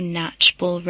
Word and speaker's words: Knatchbull: Yeah, drop by, attend any of the meetings, which are happening regularly on Wednesdays, and the address is 52 Knatchbull Road. That's spelled K-N-A-T-C-H-B-U-L-L. That's an S-E-5-9-Q-Y.